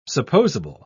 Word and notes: supposable: British English

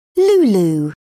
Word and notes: lulu: British English

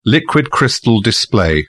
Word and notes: liquid crystal display: British English

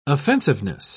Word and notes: offensiveness: British English

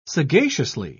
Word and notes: sagaciously: British English

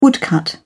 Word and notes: woodcut: British English

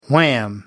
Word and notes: wham: American English

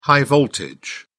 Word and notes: high-voltage: British English

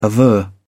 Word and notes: aver: British English

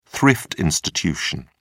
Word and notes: thrift institution: British English

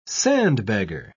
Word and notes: sandbagger: British English

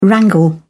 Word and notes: wrangle: British English

